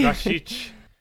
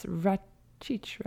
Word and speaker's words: Rašić: Yeah, to be fair, I would have pronounced it Rašić, and I think I have my whole career, but yes, now I learned it's Rašić, Rašić, Rašić.